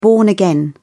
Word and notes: born-again: British English

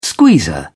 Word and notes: squeezer: British English

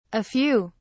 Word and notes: a few: British English

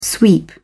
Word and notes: sweep: British English

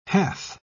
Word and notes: hath: British English

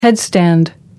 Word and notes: headstand: American English

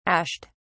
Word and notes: ashed: British English

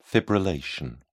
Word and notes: fibrillation: British English

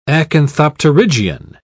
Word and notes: acanthopterygian: British English

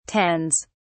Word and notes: tends: British English

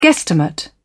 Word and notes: guestimate: British English

